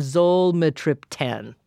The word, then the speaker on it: zolmitriptan: and then zolmitriptan.